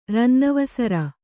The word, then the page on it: ರನ್ನವಸರ: rannavasara